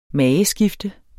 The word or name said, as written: MAGESKIFTENDE